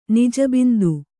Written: ನಿಜಬಿಂದು

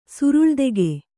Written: ಸುರುಳ್ದೆಗೆ

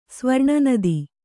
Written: ಸ್ವರ್ಣನದಿ

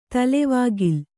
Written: ತಲೆವಾಗಿಲ್

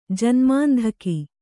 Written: ಜನ್ಮಾಂಧಕಿ

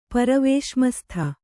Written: ಪರವೇಶ್ಮಸ್ಥ

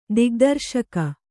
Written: ದಿಗ್ದರ್ಶಕ

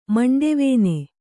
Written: ಮಂಡೆವೇನೆ

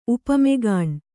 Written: ಉಪಮೆಗಾಣ್

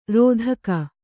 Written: ರತಿಗೈ

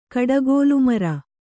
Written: ಕಡಗೋಲುಮರ

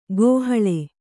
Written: ಕೋಹಾಳೆ

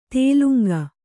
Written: ತೇಲುಂಗ

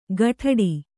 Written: ಖಡ್ಡಿ

